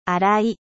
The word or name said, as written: 粗い